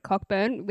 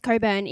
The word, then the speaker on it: Cockburn: If I was scrolling and I was from Coburn and I was really angry that two girls called it Cockburn, which is how it's spelled, I would go to the comments.